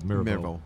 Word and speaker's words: Mirbeau: Mirbeau.